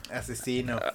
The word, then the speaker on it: Aczino: Eh, el siguiente que yo traigo es Asesina. – Asesino. – Ajá, que se escribe A-C. Z-I-N-O.